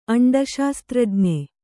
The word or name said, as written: ಅಂಡಶಾಸ್ತ್ರಜ್ಞೆ